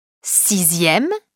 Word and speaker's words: sixième: On écrit dixième, D-I-X-I-E, accent grave, M-E, mais on prononce dixième. Sixième, S-I-X-I-E, accent grave, M-E, mais on prononce sixième. Deuxième, D-E-U-X-I-E, accent grave, M-E, mais on prononce deuxième.